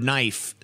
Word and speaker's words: knife: if they're too stupid to spell the word knife, still knows that the K is silent.